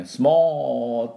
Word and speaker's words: small: small って言いながらおーって伸ばして最後は l で終わると small これですね。